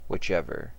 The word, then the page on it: whichever: American English